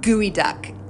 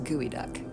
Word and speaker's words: geoduck: And everybody else out there, if you have a sick sense of humor, do yourself a favor and Google gooey duck. No, it's spelled geoduck. G-E-O-D-U-C-K. Just go ahead and do it. – I don't know what that is. – You're about to find out. – It sounds like gooey, like G-O-O. It's G-E-O-D-U-C-K, but it's pronounced gooey duck.